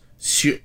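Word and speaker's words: Sjón: Is it pronounced Sean or... – On his Wikipedia page, it says pronounced Sion.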